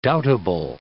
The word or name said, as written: doubtable